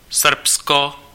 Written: Serbia

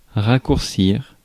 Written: abridge

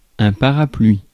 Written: parapluie

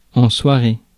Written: night

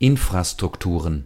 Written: Infrastrukturen

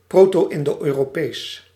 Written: Proto-Indo-European

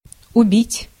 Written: abattre

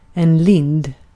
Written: lind

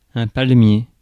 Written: palmier

